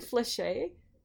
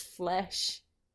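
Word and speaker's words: flèche: I learned today that I've been pronouncing a fencing term incorrectly for 6 years. I've been saying fliché. It's flesh.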